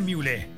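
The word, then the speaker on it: Mühle: Recuerda, se pronuncia Miule, M-U-H-L-E, Miule.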